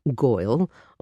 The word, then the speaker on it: girl: For those of you not familiar with dialects from New York City, goil was a way that some folks actually pronounced the word girl at the time that song was written.